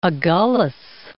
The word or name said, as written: Agulhas, Cape